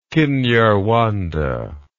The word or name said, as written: Kinyarwanda